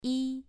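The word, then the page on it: yi: tone 2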